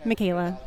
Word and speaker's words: Michaela: Michaela. – Can you please spell that for me? – M-I-C-H-A-E-L-A.